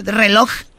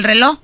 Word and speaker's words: reloj: Los nacos al reloj nunca le dicen la J, nunca mencionan la J al final, ¿verdad? Reloj. ¿Siempre dicen el reloj? – En la película de Selena se decía reloj.